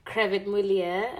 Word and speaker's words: Crevit Mulier: So maybe tell us a bit more about Crevit Mulia.